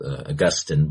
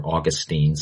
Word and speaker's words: Augustine: Some believe that originated with Augustine, by the way, or Augustine. Some people pronounce his name.